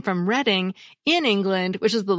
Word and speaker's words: Reading: A listener named John Commonets from Pennsylvania told me that the property in Monopoly that my family and my husband's family have always pronounced as Reading Railroad is actually pronounced Reading Railroad.